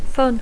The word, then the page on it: fun: English